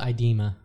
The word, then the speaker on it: IDEMA: Is it IDEMA? – I call it IDEMA.